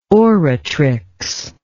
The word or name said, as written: oratrix